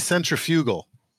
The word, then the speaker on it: centrifugal: Okay, yeah, it should be centrifugal. Like, that's the way it should be pronounced.